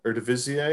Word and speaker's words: Eredivisie: All these fantastic players that have come out of the Bundesliga and the Eredivisie, is that how you say it? – Yes. Yeah, it's close enough.